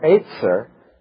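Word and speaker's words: Etzer (עזר): Simply, that's the translation of two Hebrew words, Eben, which means stone, and Etzer, if it were pronounced as it would be in the Hebrew, means helper, my stone of help, or my stone of assistance.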